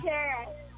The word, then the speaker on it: Karkera: Kara. – How do you spell it? – K-A-R-K-E-R-A.